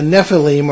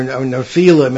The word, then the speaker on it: Nephilim: So the Nephilim, or Nephilim, as it's sometimes pronounced,